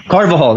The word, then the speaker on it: Carvajal: What did I say his name? Daniel Carvajal. Carvajal. That's it.